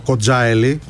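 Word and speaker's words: Kocaeli: το Κοντζάελι, όπως προφέρεται η τοποθεσία.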